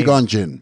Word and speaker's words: Qui-Gon Jinn: Qui-Gon, then J-I-N-N. Whatever his name is. – Qui-Gon Jinn.